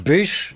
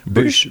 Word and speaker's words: Bûche: Bûche de Noël. Bûche de Noël. Bûche, bûche, bûche. – How would you spell it? – Bûche. B-U-C-H-E. – B-U-C-H-E.